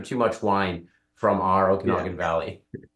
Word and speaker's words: Okanagan: And you got it correct. Okanagan is how it's pronounced. – Okanagan.